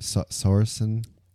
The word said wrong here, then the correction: Saoirse: Oh, Saoirse Ronan? – Saoirse. – Yeah, Saoirse Ronan.